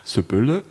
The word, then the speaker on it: Sybylle: Sybille? – Ja, richtig so.